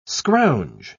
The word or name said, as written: scrounge